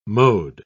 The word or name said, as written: mode